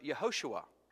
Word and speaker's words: Yehoshua: In Hebrew, the word Joshua is pronounced Yehoshua.